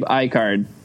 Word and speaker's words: Eichard: It's a hard I. Okay. Gabe Eichard.